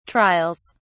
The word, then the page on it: trials: British English